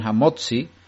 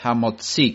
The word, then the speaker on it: HaMotzi: Conocéis esa frase, y muchos se equivocan y dicen Hamotzi, y no es correcto, es una forma americana de decir, y no es la forma correcta hebrea, sino es Hamotzi, con el acento en la i.